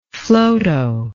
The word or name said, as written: Flotow